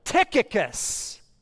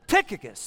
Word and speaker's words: Tychicus: Tychicus. That's how you pronounce it. Well, that's just fake it till you make it on that one, right? Tychicus.